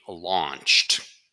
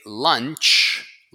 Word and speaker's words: launch: So it is being launched. Now, pronunciation check. You don't say lunch. Lunch is what you eat. This is launch.